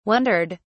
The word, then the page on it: wondered: British English